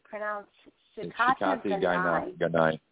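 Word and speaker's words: shikata ga nai: So I was looking, and you two might have already known this, but I was curious about what exactly Shikai Gainai means, which I'm pronouncing wrong. Let's see. – Shikati Gainai.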